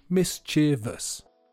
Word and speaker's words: mischievous: Often, native speakers mispronounce the word mischievous and say mischievous. But that's not how it should be pronounced. I am a bit uncomfortable telling you that the way native speakers often say the word is wrong, but according to the Cambridge Dictionary, it should be mischievous, and that pronunciation fits the rhythm of the song much better.